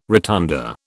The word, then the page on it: rotunda: British English